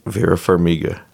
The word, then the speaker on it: Farmiga: Vera Farmiga. – Farmiga? That is not how I would have pronounced it.